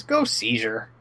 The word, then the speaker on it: Gokaiger: It's Gokaiger.